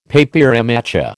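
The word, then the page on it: papier mâché: American English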